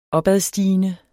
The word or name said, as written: opadstigende